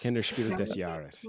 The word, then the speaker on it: Kennerspiel des Jahres: I think you pronounced the S in the DES. I don't. I practiced it with Germans when I was there. – Des Jahres. – Kennerspiel des Jahres. Yeah.